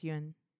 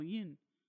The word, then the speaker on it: Nguyen: I think it's pronounced Yuen. – Yuen? Like Nguyen? – Like Yuen. – Oh, Yun. – Yeah, N-G-U-Y-E-N. – Yeah, Yun. – Yun? Yeah. Okay.